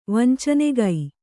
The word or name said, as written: ವಂಚನೆಗೈ